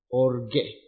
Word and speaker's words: orge: Orge. O-R-G-E.